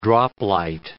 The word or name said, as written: droplight